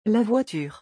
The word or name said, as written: la voiture